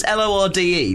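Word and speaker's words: Lorde: Is it pronounced? It's L O R D E. The New Zealand guy is very fashionable. – It's Lord, isn't it?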